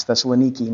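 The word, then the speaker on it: Thessaloniki (Θεσσαλονίκη): It's pronounced differently in modern Greek. It's Thessaloniki now.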